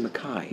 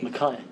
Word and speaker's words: Mackay: Well, it turns out he pronounces his last name Mackay. – Mackay.